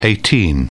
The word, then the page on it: 18: British English